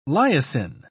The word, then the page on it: lysin: British English